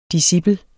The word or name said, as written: discipel